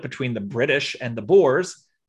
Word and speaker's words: Boers: It's spelled B-O-E-R-S, pronounced Boers, like male pigs.